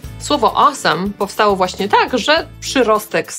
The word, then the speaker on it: awesome: Po amerykańsku wymawiamy to jako awesome, ale to już pewnie doskonale wiecie. Zwróćcie tylko uwagę, że na końcu nie jest some ani sam, tylko some. Awesome.